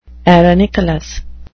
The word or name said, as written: arenicolous